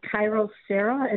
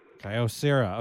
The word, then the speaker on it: Kyocera: I would like to recommend a pan I got from Wisconsin Cutlery on University Avenue, and it is called a Kyrocera. It's a nonstick pan, and I'm probably not pronouncing it correctly. – How do you spell the name? – K-Y-O-C-E-R-A. – Kyrocera.